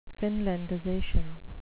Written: finlandization

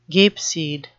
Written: gapeseed

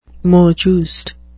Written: mot juste

